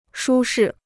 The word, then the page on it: 舒适: shū shì